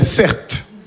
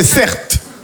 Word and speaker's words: Certe: Je m'appelle Certes. Mon prénom c'est Certes. Ça s'écrit C-E-R-T-E.